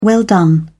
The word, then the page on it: well done: British English